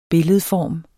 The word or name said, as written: billedform